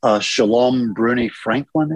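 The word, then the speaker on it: Shalom Brune-Franklin: Shalom Bruni Franklin, is it? – Yes.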